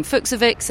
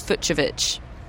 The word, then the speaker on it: Fucsovics: I had been saying Fucsovics, and it's Fucsovics.